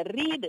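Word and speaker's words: read: He அந்த ரீட், R E A D, அந்த ரீடுங்கிற ஒரு வார்த்தையில் ஒரு மகிமை உண்டு. மூணுக்குமே ஆர்இஏடி ஆர்இஏடி ஆர்இஏடி என்று தான் வரும். ஆனால் பாஸ்ட் டென்ஸ் ரெட் என்று தான் அதை ப்ரொனவுன்ஸ் பண்ண வேண்டும்.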